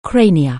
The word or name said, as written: cranium